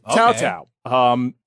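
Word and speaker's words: Cao Cao: So we're going to talk about Tao Tao, spelled C-A-O space C-A-O, pronounced Tao Tao. That's how you say it.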